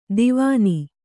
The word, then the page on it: ದಿವಾನಿ: kannada